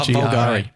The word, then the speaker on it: Bulgari: Actually, I don't know how to pronounce it. It's the one that's BLVGRI. – Oh, Bulgari.